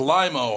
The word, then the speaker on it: Lima: It's Lima, Ohio. It's not Lima. – Oh, okay. – Oh, Lima. – It's pronounced Lima.